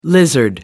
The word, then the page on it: lizard: American English